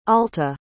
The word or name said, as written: alta